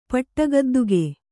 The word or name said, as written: ಪಟ್ಟಗದ್ದುಗೆ